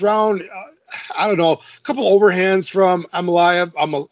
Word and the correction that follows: Almabayev: I'm saying his name wrong. Almabayev Osborne.